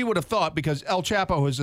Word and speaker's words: Chapo: I love that you just called him Chapo. – Whatever. Whatever. It's pronounced and spelled the same, C-H-A-P-O, Chapo.